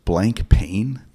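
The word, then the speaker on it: Blancpain: And also, isn't it pronounced blank pain? – Yeah. That's the official pronunciation.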